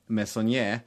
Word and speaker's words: Meissonier: Det finns den här kända franska konstnären Ernest Meissonier som är hyfsat uttal.